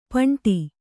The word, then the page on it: ಪಂಟಿ: kannada